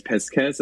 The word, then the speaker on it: Piscus: It's very like in Latin, it's Piss Kiss. It's like anti-Pisces, Pisces, but it's spelled like P-I-C or P-I-S-C-U-S, but it sounds like piss and kiss.